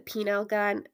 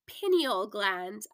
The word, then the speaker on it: pineal gland: Is it the penile gun? Sound like I'm saying penis. let me go quickly get Google to pronounce this for me and make sure I'm saying it right. Haha. Okay, I'm back. It's pineal gland. I knew there was something wrong with the way I was pronouncing it.